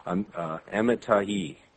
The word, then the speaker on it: Amittai: Starting in verse 1, the Lord God gave this message to Jonah, son of, it says, the pronunciation Amittai is the pronunciation given in the Bible dictionary. Amittai.